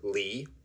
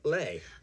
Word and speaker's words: Leigh: L-E-I-G-H is pronounced either Lee or Lay.